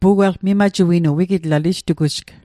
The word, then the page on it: mimajuinu: Mi'gmaq